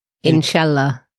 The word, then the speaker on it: Inshallah: How do you say that word? – Inshallah.